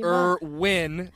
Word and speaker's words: Irwin: Irwin, emphasis on the win.